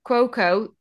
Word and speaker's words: Cuoco: Cuoco.